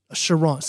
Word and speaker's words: Charente: But it exists in Southwest France, along the river Chantre. – Charente. – See, I'm so glad because I'm not pronouncing this stuff correctly.